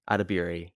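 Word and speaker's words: Edebiri: But it's got Ayo Adebayi from The Bear. – Adebiri. – I'm sorry. – That's the first time I've had to correct them.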